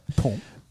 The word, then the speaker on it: Pont: Pont. – Und das T spricht man nicht aus.